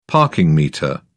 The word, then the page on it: parking meter: British English